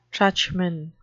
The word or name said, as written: truchman